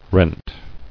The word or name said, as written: Renting